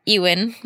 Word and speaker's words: Ewan: Ewan. – Ewan. – Ewan. – Ewan McGregor. – You know he got that growing up. – I'm just gonna like stick with that. Ewan McGregor.